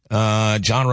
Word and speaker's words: Jon: John Roberts fan club. Remember, my name is spelled J-O-N, though.